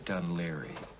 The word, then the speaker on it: Dun Laoghaire: Dun Laoghaire? – Yes, that's right, Dun Laoghaire.